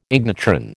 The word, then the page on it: ignitron: American English